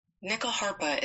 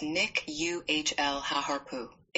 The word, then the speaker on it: Nyckelharpa: Nyckel harpa is pronounced Nick UHL Haharpu.